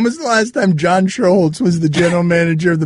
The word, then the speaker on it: Schuerholz: Schuerholz.